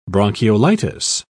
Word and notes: bronchiolitis: British English

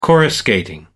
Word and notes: coruscating: American English

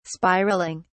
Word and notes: spiralling: British English

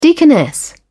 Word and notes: deaconess: British English

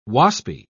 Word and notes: waspy: British English

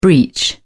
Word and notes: breech: British English

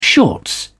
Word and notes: shorts: British English

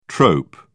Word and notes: trope: British English

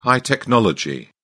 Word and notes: high technology: British English

